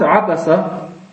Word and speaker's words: Abasa: Surat Abasa, Abasa ist ein Verb. Wie jedes andere, hat sich eingebildet, dass man Abasa sagt, obwohl man eigentlich sagen würde Abas, wenn man stehen bleibt beim Aussprechen eines arabischen Wortes, wie dem auch sei.